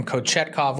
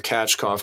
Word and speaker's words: Kochetkov: By the way, am I getting the pronunciation wrong? You're calling him Kachkov. I'm calling him Kochetkov. Which one is it? – Well, I went to NHL uh, PR to find out. So I went with Kachkov because that's what's up on the site.